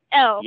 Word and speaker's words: Elle: Elle. – Yes, ma'am. – My name's Elle.